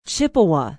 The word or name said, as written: Chippewa